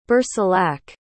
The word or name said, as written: bercilak